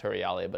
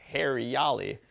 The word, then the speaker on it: Huriyali: And it's actually spelled like the, the original real Hindi word is spelled with an A. So it'd be like, if you, as it's pronounced Hurriali, but as like an English speaker reader, we'd be like, Hariali. We're like, all right, we don't want hair in our food.